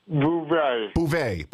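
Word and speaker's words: Bouvia: In 1983, a disabled woman named Elizabeth Bouvier, am I saying that right, Bouvier? – Bouvet. – Bouvet.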